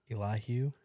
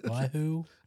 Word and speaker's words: Elihu: But if Elihu was there the whole time. – Hold on. How did you say that? – Elihu. Hugh. Who? Elihu.